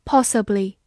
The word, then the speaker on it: possibly: Possibly。 P O S S I B L Y，possibly，P O 发 p，S S I S，B 发 b，L Y 里，possibly，possibly，美式发音字母 O 口型比较大，possibly 也是可以的。